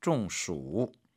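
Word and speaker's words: 中暑: 중暑. – 중暑. 발음 유의하십시오. 이때 가운데 중자는 쫑, 가운데라는 뜻의 일성이 아니라 쫑, 뭔가 적중되다, 뭐가 맞다라는 말로 해서 이제 더위를 맞았다, 더위를 먹다. 중暑.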